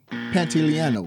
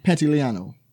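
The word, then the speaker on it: Pantoliano: Joe Pantaleone. Pretty sure I'm mispronouncing it. It's Captain Pantaleone. Pantaleano. – Okay. – Pantaleano. – All right. – Joe Pantaleano. Joe Pantaleano.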